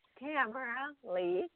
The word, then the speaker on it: Tamara Leigh: And it's Tamara Lee. – Excellent. Okay, I pronounced it wrong. I thought it was Lehi for some reason.